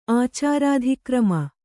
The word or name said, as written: ಆಚಾರಾಧಿಕ್ರಮ